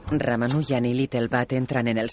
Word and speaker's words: Ramanujan: Bienvenido al Trinity, señor Ramanujan.